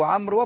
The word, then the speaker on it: عمرو: لكن واو عمرو واضحة في أن الواو تكتب ولا تنطق،